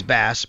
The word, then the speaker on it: Bass: For Tyler Bass, it literally says pronunciation pronounced like the fish.